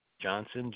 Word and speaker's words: Johnson: Travis, T-R-A-V-I-S. – Okay. – Johnson, J-O-H-N-S-O-N.